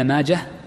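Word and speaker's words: ماجه: نعم، هذا حديث ابن ماجة، وذكرت لكم قبل أن نبدأ ماجة المحدثين ينطقونه بالهاء الساكنة مطلقا، حال الوصل وحال الوصل، حال الوصل وحال الوقف، ولا ينطق بالتاء.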